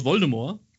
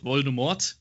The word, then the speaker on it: Voldemort: Ähm, noch ein, ein Punkt, auf den ich gern zu sprechen kommen würde, und zwar ähm, Lord Voldemort und zwar Lord Voldemort, nicht Lord Voldemort, wie es im Film ausgesprochen wird. – Voldemort. – Äh, das T am Ende ist stumm.